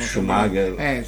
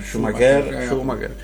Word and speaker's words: Schumacher: Un grande campione, si chiama quello che gli cambiamo Schumacher. – Gli cambiamo sempre l'accento sul cognome. – Schumacher.